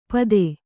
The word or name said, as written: ಪತ್ತೆ